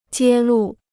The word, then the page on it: 揭露: jiē lù